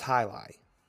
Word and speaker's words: jai alai: How do you spell the name of the sport? – J-A-I... A-L-A-I. – Jai? – It's pronounced highlight.